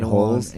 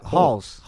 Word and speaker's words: Rolls: How do you say in Portuguese? – Halls. – Halls? – Yeah, that's what I say. – Okay. Halls. – But yeah, they're... – Not Rolls.